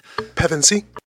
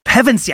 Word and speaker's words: Pevensie: Hopefully, I'm saying that right. Pevensie? Pevensie.